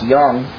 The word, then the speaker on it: Jung: It's spelled like Jung, but it's pronounced Jung.